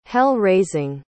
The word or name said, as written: hell-raising